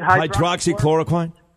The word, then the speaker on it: Hydroxychloroquine: How do you say it? – Hydroxychloroquine.